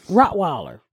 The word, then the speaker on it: Rottweiler: How do you say Rottweiler? – Rottweiler. – Huh? – Rottweiler. – She said it right. – Yeah. That's it.